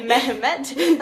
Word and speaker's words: Mehmet: And that was said by Mehmet. I don't know why I felt the need to pronounce the H there. Mehmet.